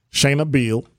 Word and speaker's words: Shana Beal: Shana Beal.